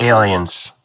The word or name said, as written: Aliens